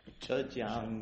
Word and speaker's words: Zhejiang: Zhejiang Machinery and Export Corporation versus United States. Tell me what your client's name is. How do you pronounce it? – Zhejiang Machinery Import Export Corporation.